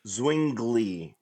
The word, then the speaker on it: Zwingli: The Swiss Reformation begins when Huldrych Zwingli. I butchered that. Zwingli. – Oh. Zwingli. Oh, shoot. – You have to say ZV together.